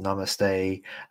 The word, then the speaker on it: Namaste: Oh man, it's pronounced Namaste. That sounds way cooler than the way that I was pronouncing it earlier, Judy.